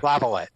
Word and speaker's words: Laviolette: How do you pronounce his name? – Laviolette. Yep. – Okay. So you do say the T. You don't. The T's not silent.